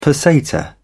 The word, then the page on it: peseta: British English